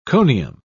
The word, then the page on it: conium: British English